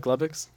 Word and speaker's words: Glubbix: Why, did you say Glowbox? – That's what I thought. Am I saying it wrong? – You say Glowbox? – Yeah, Glowbox. – I mean, I think they say Glubbix in the game. I said Glowbox because, you know, G-L-O-B-O-X.